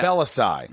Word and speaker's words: Balasai: I'm with Matt Balasai. – Yes. – Is that correct? – Balasai, yeah. – Balasai. – Yeah. – Because it's spelled Balasai. – Yeah. – Am I right? – It is spelled how it sounds.